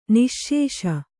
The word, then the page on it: ನಿಶ್ಶೇಷ: niśśeṣa; Kannada